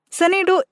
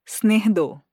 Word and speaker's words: Snehdo: The original pronunciation of the word is Snehdo, derived from the word Sneh.